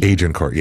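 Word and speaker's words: Agincourt: Go have lunch in Agent Court. Is that how we pronounce it? With the G? – Agent Court, yeah. – A soft G.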